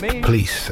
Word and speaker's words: Pleis: Jack Kenneth Pleese, it's spelled P-L-E-I-S, and he is quoted as saying it's pronounced Pleese, as in fleece or grease.